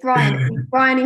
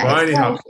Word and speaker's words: Bryony: It's Bryony Hopkins. – Bryony. – Yeah, Bryony, it's Welsh, actually.